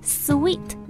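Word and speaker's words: suite: 说到这个不发音的 E 呢，我们知道套装 suit，它读成 suit，然后呢，它词尾加一个 E，大家可能知道加的这个 E 是不发音的，所以会觉得套房这个词呢，是不是还是应该读 suit 呢？这样就是不对的啊，它的发音呢和甜蜜的也就是 sweet 那个单词的发音是一样的，所以以后到酒店订房间的时候千万就不要再说错了。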